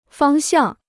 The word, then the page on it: 方向: fāng xiàng